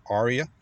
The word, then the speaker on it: Ariya: So also another car, or this is an SUV or compact SUV that Nissan just launched in 2023, is the Nissan Ariya, or Ariya, depending on your pronunciation preference.